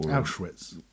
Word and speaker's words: Auschwitz: W is V, like a very harsh F for Freddy. Or Auschwitz.